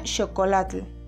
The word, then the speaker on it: chocolatl: Otro por ejemplo es chocolate, que viene del origen náhuatl y se pronuncia chocolatl.